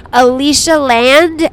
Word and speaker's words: Alicia Land: You can Cash App me at @AliciaLand, and my real name is spelled A-L-I-C-I-A Land, L-A-N-D. So it's @AliciaLand. L-A-N-D, Alicia Land.